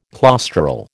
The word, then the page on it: claustral: American English